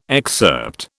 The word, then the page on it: excerpt: British English